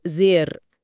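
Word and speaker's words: sehr: وكلمة جداً، زير، بتتنطق زير بمد واضح وميجد، نطق الهاء هنا غلطة شائعة جداً للمبتدئين.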